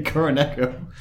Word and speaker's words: Kuroneko: Or Kuroneko. – Kuroneko? – As it's pronounced in Japanese.